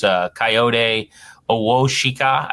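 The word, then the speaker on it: Kayode Awosika: You mentioned, I think, number 74, the guard, who's Coyote Owoshika, I believe is how you say his name.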